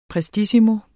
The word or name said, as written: prestissimo